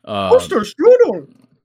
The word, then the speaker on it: Højlund: Højlund. That's how you say it.